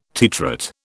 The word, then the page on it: titrate: British English